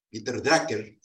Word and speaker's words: Drucker: Hay otro autor, Peter Drucker, Peter Drucker, Peter Drucker se pronuncia.